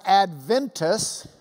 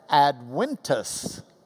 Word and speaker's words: Adventus: The Latin word looks to you, if you don't speak Latin, like Adventus. That is not Adventus. Don't say that. People will say you don't know Latin. Latin V's are pronounced like a W. So you would pronounce that Adventus.